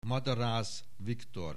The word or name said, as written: Madarász, Viktor